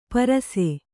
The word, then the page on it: ಪರುಸ: kannada